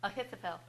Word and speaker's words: Ahithophel: Yeah, another thing I'm thinking of too, that, how did you pronounce the name? Ahithophel? – Ahithophel. – Ahithophel. – Okay.